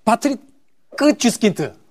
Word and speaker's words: 파트리크 쥐스킨트: 대표적인 게 파스티 어, 어, 아, 파트리크 쥐스킨트.